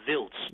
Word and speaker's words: Wiltz: It's spelled W-I-L-T-Z. It's pronounced Wiltz. W is a V.